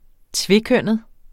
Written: tvekønnet

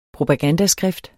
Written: propagandaskrift

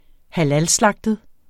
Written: halalslagtet

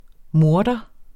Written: morter

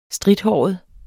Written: strithåret

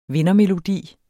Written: vindermelodi

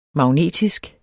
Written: magnetisk

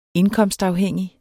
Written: indkomstafhængig

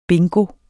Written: bingo

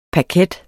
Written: paket

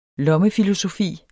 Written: lommefilosofi

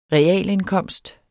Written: realindkomst

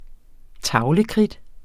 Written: tavlekridt

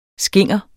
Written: skinger

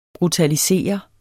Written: BRUTALISERES

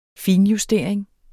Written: finjustering

